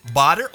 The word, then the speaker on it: Bader: Welcome back to the show, actor Dietrich Bader, or Bader. I mean, you're kind enough to allow flexibility there. – Yeah, sure. It's going to be mispronounced by everybody anyway, so it doesn't really matter. Go for it. – All right. Well, anyway, we have officially... – Bader is, I guess, the standard American pronunciation.